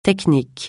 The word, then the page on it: technique: francais